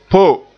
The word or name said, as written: po